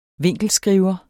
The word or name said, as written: vinkelskriver